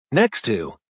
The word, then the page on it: next to: American English